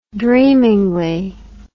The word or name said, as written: dreamingly